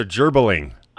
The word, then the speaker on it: gerbling: GE is the J sound. – Okay. – So gerbling.